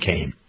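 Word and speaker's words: Kean: They spell it Keene, but they pronounce it Kane.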